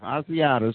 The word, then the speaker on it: Asiata: You done had Matatisa, how do you pronounce the last name, the running back, Matatisa? – Asiata, excuse me, and Jerick McKinnon.